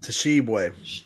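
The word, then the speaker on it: Tshiebwe: Shibwe. Did I say that right? – Tashibwe.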